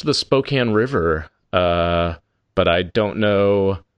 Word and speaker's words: Spokane: That was, by the way, that was the correct pronunciation of Spokane.